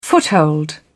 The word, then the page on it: foothold: British English